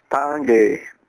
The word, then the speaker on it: Tanguay: Well, as a French Canadian, I can tell you I would pronounce it Alex Tanguay.